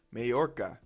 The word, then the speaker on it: Majorca: Ooh, somebody say this word for me. I almost bit my goddamn tongue. – Majorca. – The J is silent. Majorca tried to sink yacht of Russian boss.